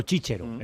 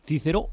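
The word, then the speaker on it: Cicero: Y ya no voy a la C que se pronuncia H. o sea, CH, que es la pronunciación medieval, que es la pronunciación italiana, ¿no? Por ejemplo, Chichero, en lugar de Cícero o Quíquero.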